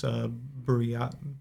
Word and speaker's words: Buryatia: It's comprised mostly of, like, indigenous people from the Sakha Republic and from, I think it's, I'm going to butcher this, Buryatia.